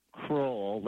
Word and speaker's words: Crowell: So, my father always said Crowell, like a crow in a well.